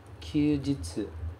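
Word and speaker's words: kyūjitsu (休日): E ele é pronunciado kyūjitsu.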